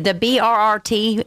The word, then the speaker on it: BRRT: And I just want to say one thing, and I'm sure I'm going to say it wrong, but BERT. That's the A-10 Warthog team. I've seen them perform at the Wings Over North Georgia Air Show a few times, and that's what it's the BRRT.